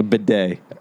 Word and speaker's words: bidet: And what I was going to say was a boudet. – And my grandfather has a boudet. – Boudet? – A bidet. – Bidet, is that how you say it? – It's a bidet.